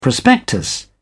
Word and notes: prospectus: British English

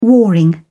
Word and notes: warring: British English

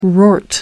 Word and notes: wrought: American English

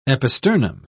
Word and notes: episternum: British English

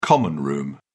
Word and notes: common-room: British English

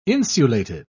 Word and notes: insulated: British English

